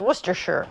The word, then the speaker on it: Worcestershire: I know that this, even though it's spelled as Worcestershire, I know it's pronounced Worcestershire.